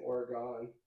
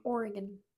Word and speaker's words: Oregon: I well um, apparently I was mispronouncing uh, Oregon wrong for my whole life because I kept saying Oregon.